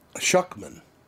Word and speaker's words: Suchman: It's pronounced Suchman. – Suchman. – Suchman. Like Shookman. Yeah.